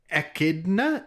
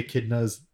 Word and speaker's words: echidna: Echidna. I think that's how you pronounce it. It's E-C-H-I-D-N-A.